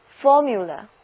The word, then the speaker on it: formula: 注意 formula 的读音，字母 U 在这里发 U， formula， formula。